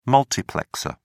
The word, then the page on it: multiplexer: British English